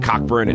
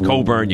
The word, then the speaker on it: Cockburn: It's not Cockburn. It's Coburn. You go with this guy.